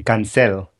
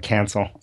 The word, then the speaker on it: Cancel: I will say that my name, I'm Hispanic, and so my name is actually Cancel. So that's the proper way to say it. But but yes, we all call it Cancel.